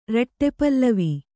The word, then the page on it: ರಟ್ಟೆಪಲ್ಲವಿ: Kannada